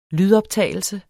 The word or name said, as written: lydoptagelse